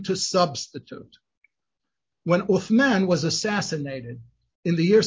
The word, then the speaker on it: Uthman: If the word Uthman sounds a little familiar, it's the Arabic pronunciation of what we better know in Turkish as Ottoman.